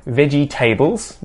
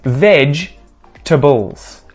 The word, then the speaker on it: vegetables: Veggie tables. Tables made of veggies. It is vegetables.